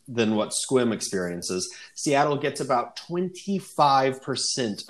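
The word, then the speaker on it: Sequim: One syllable, although it's pronounced quite differently than the way it's spelled. – Yes, exactly. – Oh, okay. It's Squim.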